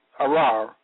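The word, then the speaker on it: arar (ארר): The Hebrew word for cursed in this text of Scripture is arar, pronounced arar, meaning to execrate, to declare to be evil or detestable, denounce, detest utterly.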